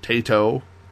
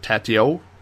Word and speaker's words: Taito: They had a bit of a conversation about how to pronounce Taito, Taito. We know that it's not Tatio, which is what Carrington said.